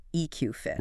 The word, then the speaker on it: EQ Fit: And just to make sure you can find it later, the company is spelled EQFIT, but you pronounce it EQ Fit.